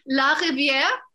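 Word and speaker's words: La Rivière: Or La Riviera or La Riviere? – I could attempt to pronounce it very incorrectly, but I will, because I'm shameless. La Riviere. – Oh, it's perfect. That's the one.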